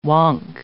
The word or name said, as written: wonk